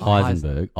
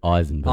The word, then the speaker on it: Eisenberg: It's not Heisenberg. Eisenberg. – Eisenberg. Okay. – Eisenberg. The E is silent.